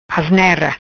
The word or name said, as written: Asnera